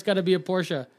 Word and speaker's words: Porsche: If it's not a Porsche, it's garbage.